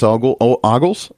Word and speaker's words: Ogles: Congressman Andy Ogles, how do you pronounce his name? I believe it's Ogles,